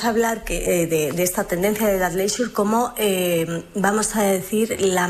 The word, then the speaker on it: athleisure: Como digo, no asustarse, queridos oyentes, se pronuncia así, athleisure, pero en realidad, aunque yo estoy diciendo todo el rato chandalismo, no es correcto, ¿no?